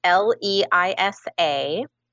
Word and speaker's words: Leisa: It's L E I S A and then my last name is R E I D.